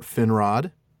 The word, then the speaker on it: Finrod: Think about Finrod, Fingon, Fingolfin, Turgon. All of them have that short O sound, that aw.